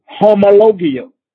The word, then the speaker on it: homologio: I think I told you guys on last Saturday, that word confession in the Greek, it is pronounced homologio.